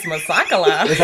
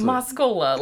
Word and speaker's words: Moskola: Moskola. Like Mos and a cola.